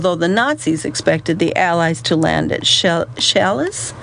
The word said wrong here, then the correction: Calais: oh, I'm not saying that correctly, spelled C-A-L-A-I-S, Chalet, a spot closer to the English coast, military commanders decided on Normandy, instead in the hopes of taking the enemy by surprise.